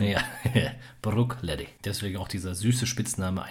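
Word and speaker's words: Bruichladdich: Also nochmal zur Aussprache, wenn man es genau nimmt, wird der erste Teil nicht bruig ausgesprochen, sondern wirklich Brook. Und dann hinten raus auch kein scharfes CH, sondern wirklich Leddy. Brookleddy. Das ist so die Aussprache, wie es in Schottland üblich ist.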